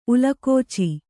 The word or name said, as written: ಉಲುಕೋಚಿ